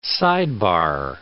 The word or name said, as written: sidebar